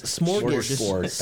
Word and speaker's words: smorgasbord: You and Jerry were the first ones to try to spell Sporgasporg. – it's smorgasbord.